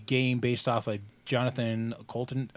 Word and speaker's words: Coulton: Coulton. Coulton. Okay, yeah. Pretend there's no you in it.